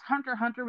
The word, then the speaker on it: Hunter x Hunter: If you don't know what Hunter x Hunter is, also it's pro it, people, it's pronounced Hunter Hunter, but it's X Hunter. I say Hunter x Hunter.